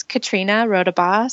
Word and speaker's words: Katrina Rodabaugh: It's Katrina Rodabaugh.